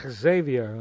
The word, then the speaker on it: Xavier: Xavier Cugat, and I'm just pronouncing the X, Xavier, I have to, clear my throat.